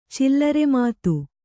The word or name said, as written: ಚಿಲ್ಲರೆಮಾತು